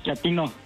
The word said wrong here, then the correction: chatino: No, chatino. – Es como chato, ¿verdad? Chatino. – Sí, azunero, como chato, chatino.